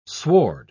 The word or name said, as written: sward